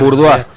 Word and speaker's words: Bourdain: Bourdain. – Anthony Bourdain, famoso chef neoyorquino, uno de los más famosos del mundo.